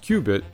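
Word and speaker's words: cubit: which is pronounced qubit, but starts with a C rather than a Q.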